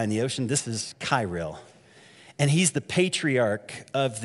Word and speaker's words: Kirill: If you want to put that picture up of Putin, and this is Patriarch Kirill. I think that's how they pronounce it. I keep wanting to say krill. It's not krill.